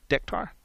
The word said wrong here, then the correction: Decatur: D-E-C-T-A-U-R. Decatur. Thank you. It's Decatur.